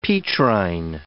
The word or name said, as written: Petrine